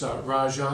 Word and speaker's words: Raja: It's Raja Salfidi. It's spelled R-A-J-A. And Salfiti's S-A-L-F-I-T-I.